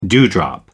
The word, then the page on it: dewdrop: American English